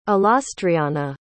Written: alastriona

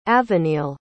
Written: aveneil

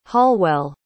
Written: hallwell

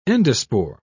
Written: endospore

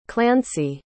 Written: clancy

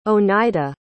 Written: onida

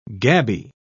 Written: gabby

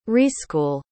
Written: reschool

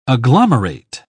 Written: agglomerate

